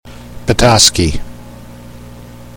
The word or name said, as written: Petoskey